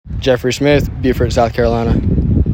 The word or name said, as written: Jeffrey Smyth